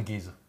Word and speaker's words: Giza: But those Giza. – Giza. – It's definitely Giza.